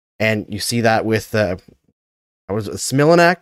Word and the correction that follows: Smilanic: Is that how you say his name? – Smilanic.